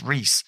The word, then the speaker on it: Reuss: No, Zachary Reese. It's Reese. It's spelled R-E-U-S-S, but I remember it was not pronounced near that.